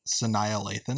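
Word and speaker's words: Sanaa Lathan: How do you pronounce her name again? – Saniya Lathan. – Saniya Lathan. With Saniya, like Annihilation, which I like.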